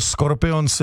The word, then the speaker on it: Scorpions: To je vlastně pravda, vždycky jsme říkali škorpíci nebo škorpioni, ale jsou to Scorpions, jako německy vysloveno.